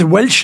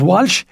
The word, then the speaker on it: Welch: It's Welsh, not Walsh, as we say in y'all.